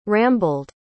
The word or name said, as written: rambled